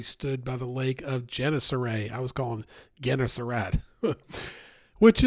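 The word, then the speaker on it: Gennesaret: It's Genesaret.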